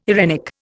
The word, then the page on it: eirenic: Indian